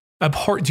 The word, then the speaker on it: abhorrent: Abhorrent blunder? How do you pronounce that? You're the word guy. – Abhorrent. – Abhorrent. Do you pronounce the H though? – Not really. – Abhorrent? – Not really.